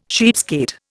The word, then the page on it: cheapskate: Indian